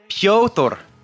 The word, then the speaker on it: Piotr: Piotr might be correct, but Piotr. – Either way. Piroto? – Piotr. – Okay. Yeah, you just do it. Yeah. That's the correct pronunciation.